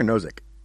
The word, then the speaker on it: Nozick: He points out that like You know, when you talk about sort of going back to the Nozick point, is it Nozick or Nozick? – Nozick, I'm pretty sure.